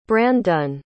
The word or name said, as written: branddun